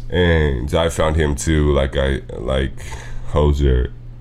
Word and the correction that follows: Hozier: I keep saying his name wrong. It's Hozier.